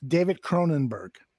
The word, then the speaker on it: Cronenberg: David Cronenberg? Is that how you say it? – David Cronenberg. – Cronenberg. – Yeah.